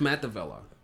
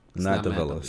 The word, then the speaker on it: Matt Devella: No, it's Matt Devella. – Matt Devella. Sorry, I was a syllable off.